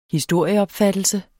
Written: historieopfattelse

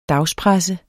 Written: dagspresse

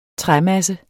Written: træmasse

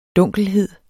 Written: dunkelhed

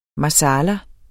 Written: marsala

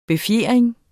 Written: befjering

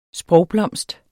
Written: sprogblomst

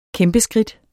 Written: kæmpeskridt

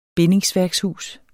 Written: bindingsværkshus

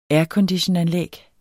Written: airconditionanlæg

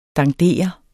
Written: dandere

